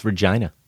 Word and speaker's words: Regina: I believe it's Regina, Saskatchewan. – Thank you. – Yeah, I'm pretty sure it's Regina.